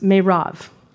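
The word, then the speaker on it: Merab: Anyway, so Saul's oldest daughter, her name is pronounced Merav. It's not spelled that way.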